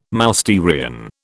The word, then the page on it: Mousterian: British English